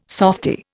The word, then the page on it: softie: Indian